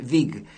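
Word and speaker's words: väg: V-A-G, pero creo que se pronuncia V-A-G con diéresis, Vig, Gadin Vig, del álbum Blommor Innomhus.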